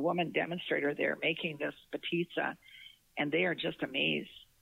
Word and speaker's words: Potica: Potica.